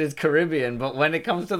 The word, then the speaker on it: Caribbean: In Walt Disney's Pirates of the Caribbean movies, the word Caribbean always is pronounced Kerr-I-B-Un.